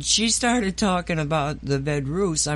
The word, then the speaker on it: Vedruss: V-E-D-R-U-S-S.